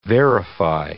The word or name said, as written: verify